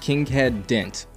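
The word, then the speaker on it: Kinkead Dent: Well, yeah, it's either going to be Plumlee or a kid named Kinghead Dent, and I believe that's how you pronounce his name. It's K-I-N-K-E or A-E-D or E-A-D rather, D-E-N-T.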